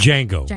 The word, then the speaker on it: Django: When Django Unchained, or Django, I think it's pronounced. – Django. – No D.